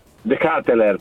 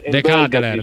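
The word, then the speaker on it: De Ketelaere: DJ, un giocatore De Ketler, perché si pronuncia così, ve lo ridico. – Vabbè, mo fa il professore, dice, su. – De Ketler, dai, De Ketler, dai.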